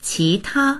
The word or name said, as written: qi2ta1